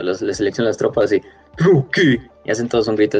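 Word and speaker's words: Drukhari: nunca supe cómo decirlo, ¿cómo se dice fácil Druki. – No, ah, pero sí es Druki.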